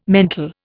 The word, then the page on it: mental: Indian